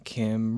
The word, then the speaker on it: Kymriah: The fact is that they came to Kymriah, which, by the way, if you ever need to sort of remember how to pronounce that, think like Kumbaya, Kymriah.